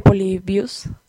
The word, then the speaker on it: Polybius: Y ya, bueno, busquen la leyenda, es una leyenda urbana de Polybius. Sí, está bien pronunciado, profesora de gramática.